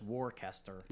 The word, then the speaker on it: Worcester: Is Worcester, but it's Worcester.